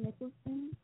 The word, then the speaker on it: Nicholson: My Facebook is Catherine Nicholson, which is spelled C-A-T-H-E-R-I-N, Faith Nicholson, N-I-C-H-O-L-S-O-N.